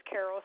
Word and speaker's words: Carol: Okay, my name is, first name is Carol, C-A-R-O-L.